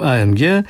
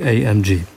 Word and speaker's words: AMG: I ustaliliśmy przed chwilą, że w Polsce będziemy go wymawiać po polsku. – Po polsku. AMG. A kiedy trasa koncertowa się rozwinie i pojadą za granicę, będą sobie mówili AMG.